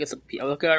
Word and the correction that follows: Bjelica: But he's Nemanja Bjelica.